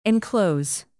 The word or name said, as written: enclose